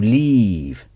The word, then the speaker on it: leave: Et partir, c'est to leave. To leave. Leave s'écrit L-E-A-V-E. To leave. Il est très important de ne pas confondre habiter, vivre, avec partir. À nouveau, le I court et le I long.